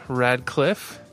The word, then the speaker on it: Radcliffe: And a special guest today, Dean Radcliffe.